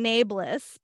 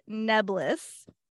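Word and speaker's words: Nae'blis: We've been saying nabless. Apparently, Robert Jordan said nebless.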